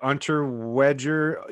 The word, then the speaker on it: Unterweger: Yeah, it's like Unterweger, Unterweger. – I think it's probably pronounced Jack Austrian. Unter Vager or something like that.